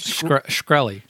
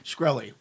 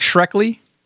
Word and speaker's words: Shkreli: and it's neck and neck between Equifax and how do you say the douche's name? – Shkreli. – Shkreli. – And it looks like Shrekley, but it's not, because that's too cute.